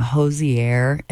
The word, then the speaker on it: Hozier: I used to call him Hozier, and we all got in trouble because all of us said his name differently at one point. So we all got the email, rhymes with Cozier.